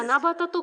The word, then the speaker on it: 七夕: 七夕と書いて七夕と読みます。